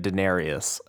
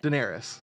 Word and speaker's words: Daenerys: Also, she keeps pronouncing it Daenerys. – How do you say it? – Daenerys. – Just Daenerys. – Pretty close. – You're saying it like it's ancient Roman currency.